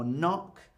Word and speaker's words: knock: So it can come at the beginning of a word, like the word gnome, or honest, or knock.